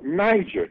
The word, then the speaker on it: Niger: Now, we pronounce that with a J, Niger, G-E-R, Niger. And the way it is, the syllables are, placed out here. It is pronounced phonetically Niger, with the enunciation and pronunciation of Niger. We give that G a J sound.